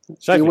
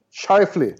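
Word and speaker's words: Scheifele: Shifley. I Winnipeg. Shifley. Shifley, ja men den, den sätter jag. Den, den har jag lärt mig. Ja. Mark Shifley.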